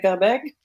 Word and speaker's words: Verbeek: I mean, I've always, growing up in Quebec, I spoke French, right? So I would say Verbeek.